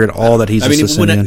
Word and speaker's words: Socinian: Socinian? – Yeah, you said.